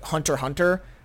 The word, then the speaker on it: Hunter x Hunter: And another one that's a little bit more adventurous is probably Hunter Hunter. But that one, it's not spelled that way. It's spelled Hunter X Hunter. Just no one calls it that.